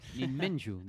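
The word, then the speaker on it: Jinmenju (人面樹): Jinmenju, dice ahí. – Jinmenju, perdón, sí, que es difícil de leer.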